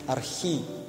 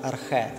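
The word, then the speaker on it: «архі» (ἀρχή): І те, що по-єврейськи «решіт» – це на грецькій мові є «архі», або якщо його по-інакше вимовляють «архе», та?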